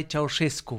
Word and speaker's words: Ceaușescu: En romano és que tenga accent Nicolae Ceaușescu.